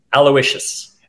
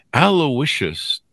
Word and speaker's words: Aloysius: Aloysius. – Aloysius.